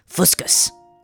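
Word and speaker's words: fuscous: It's fuscus.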